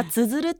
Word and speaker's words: 綴る: これはつづるって読みます。